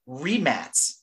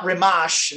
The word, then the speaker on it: Rimac: When you read it in English, it reads Mate Rimac, like we're used to pronouncing Rimac, but apparently the formerly Yugoslavian born, now, you know, Bosnia, Herzegovina, Croatia, you know, it's like changed names like 10 times. It's pronounced Matty. And the last name is Rimac. Rimac? I mean, it's not Rimash and all these other Rimac and all these variants we're talking about.